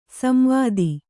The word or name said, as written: ಸಂವಾದ